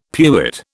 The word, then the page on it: pewit: British English